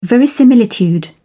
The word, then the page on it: verisimilitude: British English